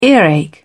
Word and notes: earache: British English

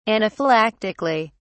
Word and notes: anaphylactically: British English

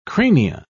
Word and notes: crania: British English

